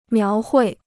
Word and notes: 描绘: miáo huì

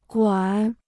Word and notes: 管: guǎn

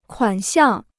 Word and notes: 款项: kuǎn xiàng

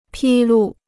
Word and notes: 披露: pī lù

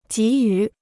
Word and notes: 给予: jǐ yǔ

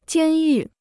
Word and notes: 监狱: jiān yù

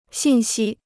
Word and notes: 信息: xìn xī

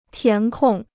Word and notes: 填空: tián kòng

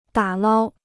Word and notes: 打捞: dǎ lāo

